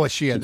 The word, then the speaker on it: Ashiya: Well, there's a lot about Ashiya online. Whether any of it's relevant here, I don't know. But um, Ashiya is a city in Japan. Oh, Ashiya.